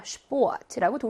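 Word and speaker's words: Sport: Sport라고 독일어식으로 발음 잘 해주셔야 합니다.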